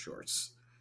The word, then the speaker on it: Schwartz: Yeah, he's, unless I'm saying his name wrong. Yeah, Sherwood Schwartz is his name.